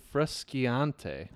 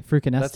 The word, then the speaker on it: Frusciante: It's Frusciante. – Frusciante. Frucaneste? That's, no, it's not frucaneste.